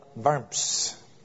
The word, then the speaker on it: Worms: If I were to pronounce it more accurately, it would probably be the Diet of Worms.